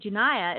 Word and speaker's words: Junia: Junia, that's J-U-N-I-A. It's pronounced Junia, was identified by Paul as an apostle in Romans 16:7.